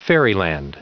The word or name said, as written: fairyland